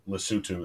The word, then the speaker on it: Lesotho: It's Les, Lesotho, but it's Lesotho is how it's pronounced.